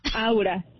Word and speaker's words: Aura: Oigan, en buena onda, dígame si soy la única mensa que ha dicho farmear áurea. – No, yo también. – Farmear áurea. Yo no he escuchado. – Es que está difícil de pronunciar, la verdad. – Farmear Aura. – Aura. – Aura. – Yo dije, a ver, Aurea, ya me tocaron tres Aura, personas que lo han dicho.